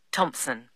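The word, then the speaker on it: Thomson: Janet Thompson. – Is that Thompson spelled with a P? – No, it's T-H-O-M-S-O-N.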